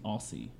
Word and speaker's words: Aussie: Spell it because it sounds like you're saying Aussie. – Yeah, like Aussie, like uh, Australian.